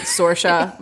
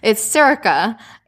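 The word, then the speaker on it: Saoirse: So I'm just like phonetically, Sorsha. – Well, I did too. I pronounced it Sorsha myself. Which it's not. – It's Sirica, and I'm sure that I'm not rolling my R enough, but it doesn't matter.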